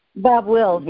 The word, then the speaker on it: Bob Wills: the Wilder Willis, not sure how you pronounce his last name. – Bob Wills, Wills? – Yeah.